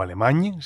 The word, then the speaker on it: Alemany: Jesús Alemany, o Alemany, si lo pronunciamos conforme al origen catalán del apellido.